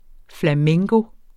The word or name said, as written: flamenco